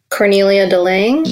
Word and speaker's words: Cornelia de Lange: Cornelia de Lange.